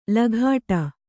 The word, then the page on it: ಲಘಾಟ: laghāṭa; Kannada